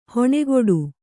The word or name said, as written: ಹಣೆಕಟ್ಟು